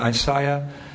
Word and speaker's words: Isaiah: Isaiah, is that Isaiah? Is that how you say it? – Yes. – Yeah, Isaiah.